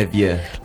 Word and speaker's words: Le Vieux: Kind of sadly, those two songs about being young and growing up led me to the Jacques Brel song, Le Vieux. Is that how you say it? – Le Vieux. – Le Vieux, Le Vieux.